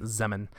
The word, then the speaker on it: Zeman: So it was first described by, I want to say Adam Zeman. That's how I'm going to pronounce it. – Yeah, let's go with Zeman. – Zeman.